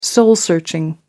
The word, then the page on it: soul searching: American English